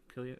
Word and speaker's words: Cillian: How do you pronounce his name? Killian? – Killian, okay. – It's Irish.